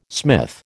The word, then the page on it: smith: American English